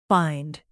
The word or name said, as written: bind